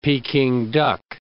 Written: Peking duck

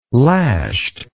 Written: lashed

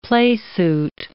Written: playsuit

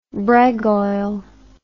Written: bregoil

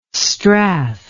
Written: strath

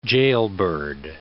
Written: jailbird

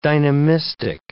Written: dynamistic

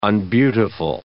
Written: unbeautiful

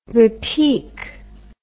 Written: repique

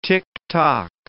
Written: ticktock

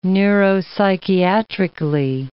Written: neuropsychiatrically

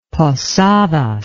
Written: Posadas

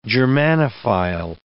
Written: Germanophile

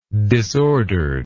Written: disordered

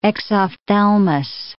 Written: exophthalmus

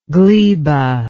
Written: glebal

